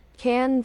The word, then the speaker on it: Cannes: Cannes Film Festival. – I think you're hearing the S. – Oh, well, I think not. – It's spelled, you know, the phonetic is K-A-N, then film, and then festival.